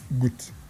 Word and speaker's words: Gut: G-U-T. Is it Got or Goot? Goot. Okay. G-U-T United.